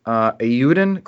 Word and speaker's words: Eiyuden: Eiyuden Chronicle, did I pronounce that correctly? – Sounds right.